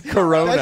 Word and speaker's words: Corona: Well, because if you're worried about there being a food shortage, yeah, because if the Kenora virus makes Kenora, Ontario? – What's it called? A small town. – How do you say it? – Corona.